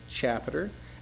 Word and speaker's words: chapiter: It's pronounced like chapter, but it's chapiter.